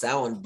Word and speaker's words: Samhain: And it's funny because I always thought it was pronounced Samhain as well. And it really it is pronounced Samhain.